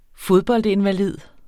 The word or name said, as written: fodboldinvalid